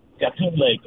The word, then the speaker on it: Gatun Lake: Gatun Lake, am I saying that right? – Gatun Lake. – Correct. Gatun Lake.